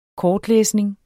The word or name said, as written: kortlæsning